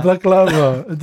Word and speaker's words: baklava: Baklava, that's how you pronounce it.